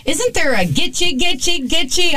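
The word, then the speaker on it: Gitchie: I'm covering the Gitche Girl and the murders at Gitche Manitou State Park.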